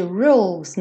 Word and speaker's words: rules: Lembra de rules, não pronunciar o E. Então, rule, chegou no L, já vai para o S.